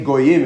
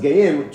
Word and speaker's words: goyim: So the word Shnei Goyim is the way that it's pronounced, but it's pronounced Geim, two proud nations.